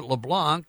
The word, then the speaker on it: LeBlanc: She is Beth LeBlanc, and it's a French last name, L, little e, capital B as in boy, L-A-N-C, and she pronounces it LeBlanc. That's the way you should pronounce it.